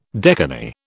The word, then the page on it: decani: British English